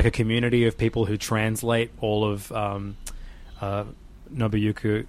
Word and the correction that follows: Nobuyuki Fukumoto: Am I even saying his name right? It's so hard just remembering all the characters. Yeah, Nobuyuki Fukumoto.